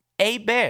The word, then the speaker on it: Hebert: No R. A Bear.